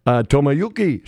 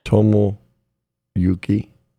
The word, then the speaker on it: Tomoyuki: Tomoyuki Sugano. Nailed it. – Nice. – Taking on Connor. – Say it slow for Doug. – Tomoyuki Sugano. – Can you spell it? – T-O-M-O.